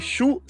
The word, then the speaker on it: Shu: Claro, nome dele é o grande Shu Sakuratani. Na verdade, ele se escreve Siu, mas se lê Shu.